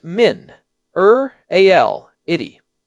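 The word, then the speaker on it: minerality: Fifth and final vocabulary term, and this one is a bit more advanced, but it is a beautiful word, pronounced min, al, itty.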